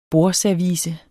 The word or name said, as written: bordservice